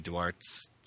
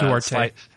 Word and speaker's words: Duarte: I mean, that's when I picked up Nancy Duarte's I don't know how to pronounce her last name, uh, but uh, Duarte.